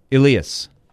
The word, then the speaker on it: Elias: It's spelled E-L-I-A-S. He says, if you're, he uses more of the Swedish pronunciation, Elias.